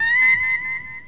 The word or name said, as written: turn